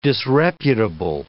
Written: disreputable

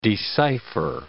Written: decipher

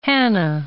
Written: Hanna

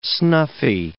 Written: snuffy

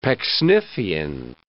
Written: Pecksniffian

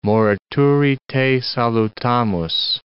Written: morituri te salutamus

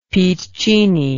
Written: piccinni

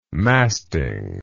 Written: masting

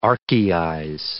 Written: archaize

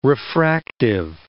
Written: refractive

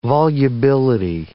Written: volubility

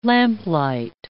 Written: lamplight

